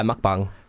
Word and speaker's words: mukbang: Is it mukbang? Is that how you pronounce it? – Yeah, mukbang.